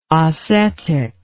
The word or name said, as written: Osetic